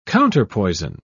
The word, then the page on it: counterpoison: British English